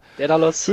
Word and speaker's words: Daidalos: Der eine ist mit seiner Familie auf Kos im Robinson Daidalos. Ich glaube, so spricht man das richtig aus. Kann das sein? – Ja, gut. – Ja? – Daidalos.